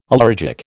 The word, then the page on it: allergic: American English